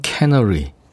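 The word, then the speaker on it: canary: 카나리아가 이제 우리말로 카나리아인데 영어로는 캐너리 그렇게 발음하더군요.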